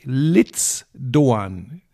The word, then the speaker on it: Ritsu Doan: Der oh, da war Japaner vom SC Freiburg schreibt sich Ritsu Doan. Liebe Grüße von meinen japanischen Freunden. Er spricht sich tatsächlich Litz Doan. Klingt komisch, ist aber so.